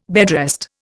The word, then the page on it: bedrest: Indian